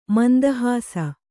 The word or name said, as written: ಮಂದಹಾಸ